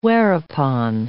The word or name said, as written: whereupon